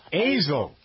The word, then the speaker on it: Azle: Azle.